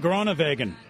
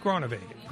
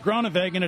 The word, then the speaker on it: Groenewegen: Dylan Groenewegen. Like Farfignugen. – No, like Groenewegen. – Groenewegen it is.